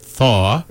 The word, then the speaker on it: Thau: Dr. Stephen Thaw, T-H-A-U, but he pronounces it Thaw.